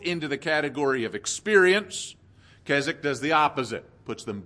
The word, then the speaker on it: Keswick: There is an opposite pole, which is, I'm going to call the Keswick model, that W is silent, Keswick model, where great emphasis is placed on the legal or forensic aspects of salvation.